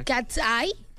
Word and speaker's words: Katseye: Pueden poner Gabriela de Katsei. ¿Katsei? ¿Cómo se pronuncia? – Katsai.